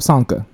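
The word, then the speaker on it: Csonka: When the Dolphins went undefeated, let's go say the C is silent. I'm gonna mess it up. Sanka.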